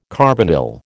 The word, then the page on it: carbonyl: American English